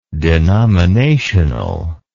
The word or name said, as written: denominationally